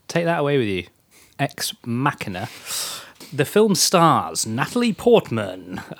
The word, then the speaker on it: Ex Machina: After his much-acclaimed Ex Machina, or Ex Machina? – It's Ex Machina, cool. – I believe. – So I pronounced it right the first time.